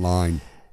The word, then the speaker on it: Lyne: Adrienne Lynn, of course, does a lot of exotic films, or Exotica-esque thrillers. – Erotic. – Exotica, erotic. – It's pronounced Adrienne Lyne. Lyne. – Lyne? – Yeah, Adrienne Lyne.